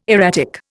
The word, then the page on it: erratic: Indian